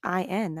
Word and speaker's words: Samhain: But it's Sow-in. That's the pronunciation.